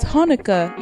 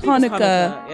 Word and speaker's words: Hanukkah: Do you know how to pronounce it? – I think it's spelled as Hanukkah. – It's Hanukkah. Yeah, it's Hanukkah.